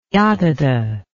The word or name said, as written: yatata